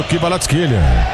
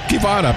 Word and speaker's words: Kvaratskhelia: Gostei da pronúncia aí do Kvaratskhelia. Mas tem gente que prefere falar Kivara apenas.